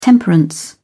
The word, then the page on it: temperance: British English